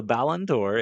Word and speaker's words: Ballon d'Or: It's called the Ballon d'Or.